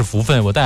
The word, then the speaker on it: 福分: 当时还有好多家长、因为家长会带孩子去水族馆说这个，当时我念错了福分嘛、当时念的是福奔还是怎么来说，这个是福分，我带孩子去过，你要记住这个读音、所以我印象特别深刻，因为魔鬼鱼真的就像在海里展翅遨游的感觉吗？